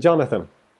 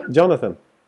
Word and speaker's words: Jonathan: Och ja, då sa jag, what's your name? It's Jonathan. Jamaica? No, Jonathan. Jamaica? No, Jonathan.